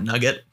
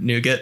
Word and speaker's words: nougat: So I was calling it Nugget. And then I heard Nougat and I was like, oh, all right. Got to pronounce that differently.